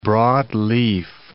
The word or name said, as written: broadleaf